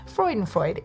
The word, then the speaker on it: Freudenfreude: Freudenfreude. It's spelled F-R-E-U-D-E-N-F-R-E-U-D-E.